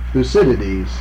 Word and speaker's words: Thucydides: The Greek, Thucydides. Thucydides.